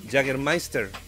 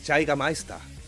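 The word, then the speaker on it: Jägermeister: Jaggermeister. Es Jaigameister.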